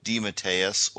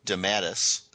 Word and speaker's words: DeMatteis: DeMatteis or J.M. DeMatteis, kind of interchangeably. He doesn't mind either way. DeMatteis is the Italian pronunciation, but he has also gone by DeMatteis.